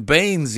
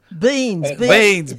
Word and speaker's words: beings: I kept saying human beings yesterday. – He kept saying that one of the problems is that we're human beings. – Beans, B-E-A-N-S. – That's the way he pronounces it. He keeps saying beans.